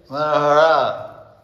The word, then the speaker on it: Manohara: Manohara. Long A.